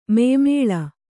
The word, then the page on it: ಮಾಯಾಮಲ: mayāmala